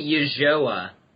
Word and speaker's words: Ulloa: How do you say it? – It's actually Ulloa.